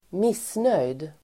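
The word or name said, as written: missnöjd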